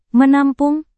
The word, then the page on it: menampung: Indonesian